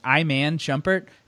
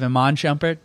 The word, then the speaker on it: Iman Shumpert: Did have one mispronounced name. You went with something like Iman Shumpert instead of Iman Shumpert, but it's all good.